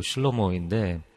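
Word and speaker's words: 슐러모인데 (שלמה): 솔로몬이라는 이름을 우리가 보고 있는데 사실은 이 솔로몬이 영어 발음이 이제 솔로몬이고 히브리어로는 슐러모입니다. 그래서 뒤에 N 사운드가 이제 몬의 그 N 사운드가 없고 슐러모인데 어, 샬롬이라는 단어에서 나온 말입니다.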